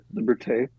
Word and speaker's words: Liberté: That's it, Liberté.